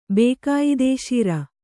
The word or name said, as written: ಬೇಕಾಯಿದೆಶೀರ